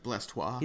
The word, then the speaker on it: Blastoise: Yeah, it's pronounced Blastoise.